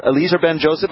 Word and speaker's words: Eliezer Ben-Joseph: Dr. Eliezer Ben-Joseph, have I said it correctly? – Yes, you did.